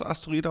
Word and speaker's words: Asteroid: Und zwar steht DART für Double Asteroid, spricht man so aus, Asteroid auf Englisch?